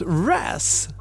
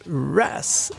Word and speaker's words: Ruess: Nej, säger man Nate Ruse. Just give me a reason. Vi har ju sån hjälp ifrån musikläggarna. Nate Ruse. R-U-E-S-S. Det var inget vanligt uttal.